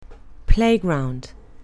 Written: playground

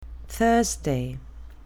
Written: thursday

